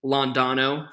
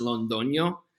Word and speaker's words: Londoño: So it's commonly, and I accept it, it's been commonly Londano for a very long time. My family, my father in Spanish, it's pronounced Londoño, which is of Colombian descent, but I've gone with Londano for a very long time, being born and raised in America, you know, so I go with either.